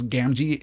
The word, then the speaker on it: Gamgee: referring to the repetition of the letter G here in Sam's name. One hard, one soft. That makes a lot of sense. Gamgee is the accepted pronunciation for Sam's last name.